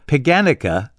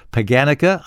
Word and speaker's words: Paganica: Yeah, it was invented by Roman soldiers as a way to relieve the monotony of camp life, and it's believed that a version of Paganica, I believe that's how they pronounced it, although nobody from back then is around to tell me I'm wrong, it's spelled P-A-G-A-N-I-C-A.